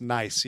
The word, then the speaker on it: Nice: Oh, is it nice? – It's pronounced nice, yeah. – Just like it sounds. The city's so nice, they called it Nice.